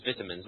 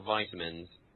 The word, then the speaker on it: vitamins: I said the British pronunciation, which is vitamins, not vitamins. Because you don't put that much emphasis on the I.